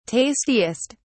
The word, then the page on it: tastiest: British English